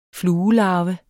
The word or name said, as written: fluelarve